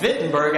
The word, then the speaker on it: Wittenberg: All right, all German words start with W, but they're pronounced with V. All right, so he marched from his home in Wittenberg a half mile and hung the 95 theses on the front door of the church there.